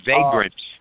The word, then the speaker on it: vagrant: He says, I'm proposing a 6-month common sense measure that will immediately begin to remove the chokehold that vigrants, as I'm pronouncing that right, vigrants have on our city. – It's vagrant. Vagrant or vagrancy.